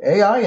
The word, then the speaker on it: Ai: What did he say it was? – AI. – AI. – Mm-hmm. – Okay, AI. It is.